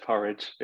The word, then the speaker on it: Porij: Yeah, just like the food, pronounced Porij. Yeah, spelled P-O-R-I-J.